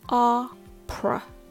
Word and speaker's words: opera: It has the P and the R sounds, followed by uh, which is called the schwa sound. So it sounds like this. per uh, per pr, pr, opera, opera.